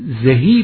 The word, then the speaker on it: زهی: البته زه درست دستی را میگوییم زها زه، بنده پیوسته به تلفظ زه میخوانم، شاید دقیق نیست، زیرا میگوییم زها زه، خهاخه، مهامه. در نتیجه زهی باید خواند.